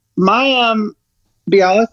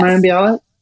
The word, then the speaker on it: Mayim Bialik: It's that Mayim Bialik, is that how you say her name? – Yes. – Mayim Bialik? – Yes.